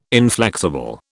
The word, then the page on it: inflexible: British English